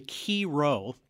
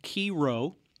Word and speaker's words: Chi Rho: And the first one I want to talk about is the chiro. That's spelled C-H-I-R-H-O, chiro.